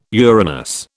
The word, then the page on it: Uranus: British English